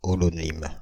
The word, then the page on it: holonyme: [ɔ.lɔ.nim]; Paris; France (Île-de-France); /ɔ.lɔ.nim/